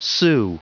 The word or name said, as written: sue